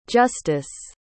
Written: justise

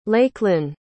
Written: Lakelynn

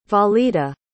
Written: valida

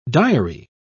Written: diary

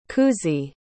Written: kuzih

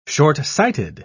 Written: shortsighted

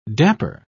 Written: dapper

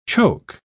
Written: choke

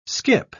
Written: skip

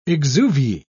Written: exuviae

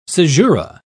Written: caesura

